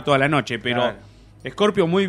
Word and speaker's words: Scorpio: Vamos con Scorpio, ¿cómo viene Scorpio? – Muy bien, qué bien que lo venís pronunciando. – Sí, viste, después se decía Scorpion.